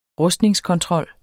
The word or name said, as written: rustningskontrol